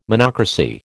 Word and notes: monocracy: American English

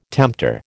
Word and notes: tempter: American English

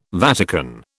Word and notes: Vatican: British English

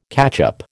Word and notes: catchup: American English

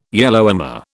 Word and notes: Yellowhammer: British English

